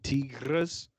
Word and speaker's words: tigres: But yeah, man, supposedly she fed her husband to the old tigres. That's how they say it in Spanish.